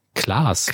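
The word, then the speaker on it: Klaws: Ziehen Klaas. Klaas.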